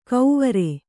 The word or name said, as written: ಕೌವರೆ